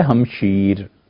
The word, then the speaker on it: ہمشیر: وگرنہ اصل میں لفظ ہے ہمشیر، دودھ میں شریک، ہمشیر بہن کے لیے بولا جاتا ہے. حتیٰ کہ جو فارسی تلفظ کی بڑی ڈکشنریز ہے نا، آپ اس میں دیکھیں، نہیں مانتے اہل، وہ کہتے ہیں ہمشیرہ کوئی لفظ نہیں.